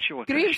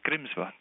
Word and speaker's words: Grímsvötn: It looks like one's pronounced Grímsvötn. Shorter word to pronounce. – Grímsvötn? – Grímsvötn.